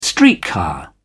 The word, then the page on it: streetcar: British English